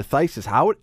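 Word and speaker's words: phthisis: But the first one, I believe, is pronounced thesis or thysis. Is that right? – Yes, that was one that I got last year. – Okay, last year. All right. So last year, thysis. Now, I would think that it would be like T-H-A-I-S-U-S.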